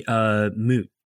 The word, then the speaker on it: Muut: And this tied to the concepts that Don introduced of how do you say moot there? M-U-U-T.